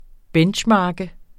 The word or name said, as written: benchmark